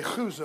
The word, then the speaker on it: Chuza: Sorry, on the front row there when I say Chuza. That's how they say it in Hebrew.